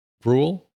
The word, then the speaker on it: Gruel: Is it like Cruel? Gruel? – Gruel, like the porridge.